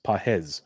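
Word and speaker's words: Pages: And it's, it's Pajes. Pajes. Pajes is how you pronounce it on Baseball Reference.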